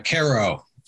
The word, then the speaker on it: Karo: It's Karo, even though.